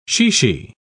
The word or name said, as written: chichi